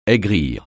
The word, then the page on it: aigrir: français